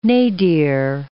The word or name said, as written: nadir